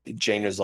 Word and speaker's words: Django: Yeah, when he's at the bar and the guy goes, Django's like, Django, the D is silent.